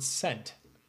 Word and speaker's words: scent: Is the S or the C silent in the word scent? – Well, what was your instinct answer? – C. I mean, I think you pronounced the first letter, right? – Yeah. Apparently, sort of like Tom, who maybe has some linguistics perspective, says... – She does, yeah. – The C is silent because it was a later addition grafted onto a word with Latin origins.